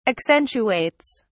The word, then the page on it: accentuates: British English